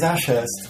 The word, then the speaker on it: Ashurst: My surname is Ashurst, and everyone in Norway can't pronounce the Hurst bit, so they call me Ashurst.